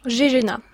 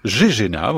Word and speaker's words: GGENA: Vous êtes donc doctorante à l'université de Reims-Champagne Ardennes, dans un laboratoire, on prononce comment le nom de votre labo? – GGENA. – GGENA, voilà, ouais.